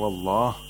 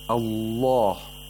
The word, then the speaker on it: الله: except in the majestic name of Allah, under certain conditions. For example, if Allah, the name of Allah, is preceded by fatha or dhamma, we pronounce the lam with taghliu, which is the equivalent of tafkhim, but we use taghliu for lam.